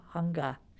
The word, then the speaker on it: hanga: And ended up going to Japan in 1988 to learn more about traditional Japanese woodblock, which is called moku hanga. Hanga rhymes with manga, and moku means wood, and hanga means print.